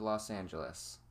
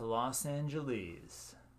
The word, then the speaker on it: Los Angeles: Coming into Los Angeles. – Oh, it's Los Angeles.